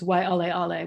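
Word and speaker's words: why ale ale (Waiʻaleʻale): In Hawaiian, I guess it's the W if it's the beginning of the word, but it's pronounced V if it's in the middle. So it's why ale ale, one of the wettest spots on earth, apparently, on there Kauai.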